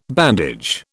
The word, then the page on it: bandage: British English